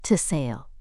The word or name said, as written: to sail